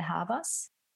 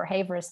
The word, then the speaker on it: Havers: So the German pronunciation would be Isabelle Havas. – Isabelle Havas. – But if you go for Havers, that's totally fine, because I guess more English-speaking people could find me.